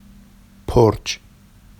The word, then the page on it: porʧ: Vafsi